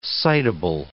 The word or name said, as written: citable